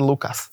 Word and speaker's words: Lucas: tak tam sa v jednej časti sa to vyslovuje, že Lukáš takým, nie že š, ale také nejaké zaoblené. Ale že všade inde sa hovorí Lukáš.